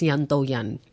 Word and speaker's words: Yanto Yan: Actually, it's pronounced Yanto Yan in Cantonese, which basically means Indian. If you just ask your Chinese colleague or someone around, how do you say Indian in Cantonese? They will definitely tell you it's Yanto Yan. So with a little bit more of a D sound, whereas we Indians, we pronounce it with the T sound.